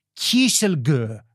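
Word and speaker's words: Kieselgur: Μπορεί να τη βάλει μέσα έτσι σε ένα πράγμα το οποίο το ονόμασε, περιμένω να σας το πω σωστά, Kieselgö, αυτό το πράγμα, το πολύ ξεκάθαρη σουηδική προφορά, την ακούτε, το Kieselgö,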